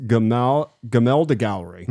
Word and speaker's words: Gemälde: It's pronounced Gemälde Gallery.